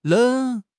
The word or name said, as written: Leng3